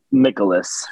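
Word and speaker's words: Mikolas: He's either Michaelis or Michaelis. – I pronounce it Michaelis. – Okay, Michaelis.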